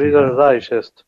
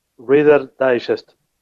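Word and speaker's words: Reader's Digest: esas elecciones del Real Digest, eh, como mierda se pronuncia, eh, que no me acuerdo cómo se dice. – Reader Digest. ¿Cómo? Reader Digest.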